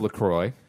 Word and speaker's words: LaCroix: First of all, it's pronounced LaCroix, okay? – And it's pronounced pussy.